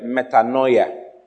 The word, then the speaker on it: metanoia: The word repentance is from the Greek word metanoia. Metanoia. It's spelled M-E-T-A-N-O-I-A. M-E-T-A-N-O-I-A. – Wow. – Hallelujah. – Hallelujah. – Repentance, what? Metanoia. M-E-T-A-N-O-I-A.